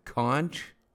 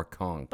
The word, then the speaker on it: conch: Okay, but I'm interested to know whether your teacher pronounces it conch or conch. – Both ways. – Do you go both? Because you can pronounce it either way. – Yeah. – And some kids learn Piggy's got the conch, and others Piggy's got the conch. It's C-O-N-C-H.